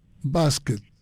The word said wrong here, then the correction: Bastet: Bastet. – ¿Cómo se escribe? – B-A-Bastet. – Bastet. – Bastet. – B-A-S-T-E-T, Bastet.